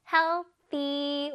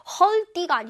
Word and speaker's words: healthy: healthy. 우리 친구들 잘해. healthy. 발음이 좀 어렵지. 헐띠가 아닙니다. 헐띠가 아니라 healthy.